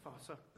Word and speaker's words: Fossa: Fossa. – Fossa. – Florida, O-S-S-A.